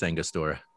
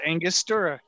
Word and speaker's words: Angostura: Label and I was like, oh my God, it is Angostura. Because I did is. The same you're right. – thing. – You're right. It is Angostura.